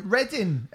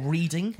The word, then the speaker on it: Reading: Reddin, everyone. Hey, Reading and Leeds Festival's announced. – Or Reading, as Fred Durst pronounced it.